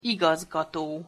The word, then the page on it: igazgato: Hungarian